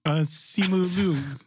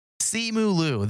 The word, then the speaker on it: Simu Liu: Simu Liu? Simu Li? How do you say his name? I'm going to ask you because I'm racist. – Simu Liu. – Simu Liu, the actor from...